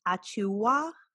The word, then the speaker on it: Achiuwa: You pointed out LaMelo Ball, Jaden McDaniels, Cole Anthony, Precious Achiuwa. I hope I said that right. – Yep, that's right.